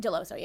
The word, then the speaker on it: Dell'osso: Dell'osso, yeah.